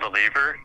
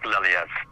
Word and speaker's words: Lelievre: It's anglicized. It's Lelievre, but it's supposed to be pronounced like Lelievre.